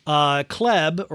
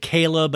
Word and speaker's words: Chleb: Kleb or Caleb or Chubbs, however you want to call it. We say it Caleb, but it's spelled C-H-L-E-B because they hate us.